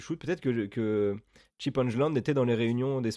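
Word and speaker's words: Engelland: Euh, je pense que c'est ça la prononciation parce que Benoît euh, dans le dernier podcast nous a, dit Angeland. Alors je pense que c'est ça parce qu'il est québécois, il parle mieux anglais que nous.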